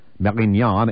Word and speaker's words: Marignane: In the summer of 1995, Front candidates won mayoral races in three fair-sized southern towns, Marignan, M-A-R-I-G-N-A-N-E, Orange, spelled like orange, and Toulon, capital T-O-U-L-O-N.